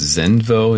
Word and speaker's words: Xenvo: So the brand is, I think it's Zenvo is how you say it, X-E-N-V-O.